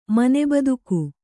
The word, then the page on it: ಮನೆಬದುಕು: kannada